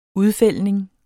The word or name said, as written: udfældning